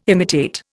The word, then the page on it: imitate: Indian